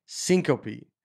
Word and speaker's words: syncope: It's pronounced syncope, meaning to faint. Like goats, the fainting goats, syncope.